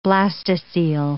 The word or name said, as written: blastocoele